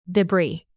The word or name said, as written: debris